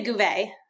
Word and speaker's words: Gouveia: You can say Gouveia.